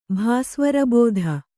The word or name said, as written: ಭಾಸ್ವರಬೋಧ